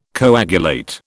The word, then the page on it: coagulate: British English